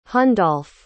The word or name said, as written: hundolf